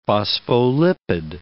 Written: phospholipid

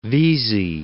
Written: Vesey

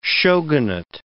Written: shogunate

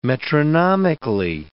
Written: metronomically

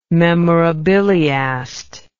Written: memorabiliast

